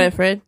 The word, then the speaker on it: Winifred: Winifred, right? Am I saying this right? – Winifred, yeah.